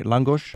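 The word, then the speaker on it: lángos: Peter, introduce us to am I pronouncing it right? Langos? – Perfect, yeah.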